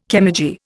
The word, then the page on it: chemurgy: Indian